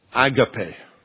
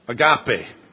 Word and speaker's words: agape: Agape. Some people put the emphasis on a different syllable. Agape. Agape.